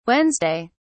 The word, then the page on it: wednesday: British English